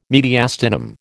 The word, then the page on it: mediastinum: American English